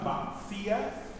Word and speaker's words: facies: That's the Veris Lenta Facias. Notice how I said facias, even though it says F-A-C-I-E-S, we pronounce the C like a T-S sound.